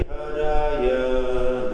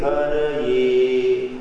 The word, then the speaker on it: Haraye: The proper mantra is, a proper pronunciation is Hari Haraye Haraye. When you say Haraye, that is unto Hari, the Supreme Lord, Sri Krishna.